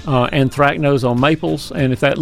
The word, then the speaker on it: anthracnose: This is a very hard word to spell, but I can pronounce it very nicely. It's anthracnose. Anthracnose, A-N-T-H-R-A-C-N-O-S-E, but anthracnose is a spotting disease.